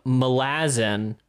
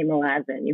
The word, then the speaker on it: Malazan: I noticed you hit it with the Malazan, the way Steve did it. – Yeah, you got the correct pronunciations.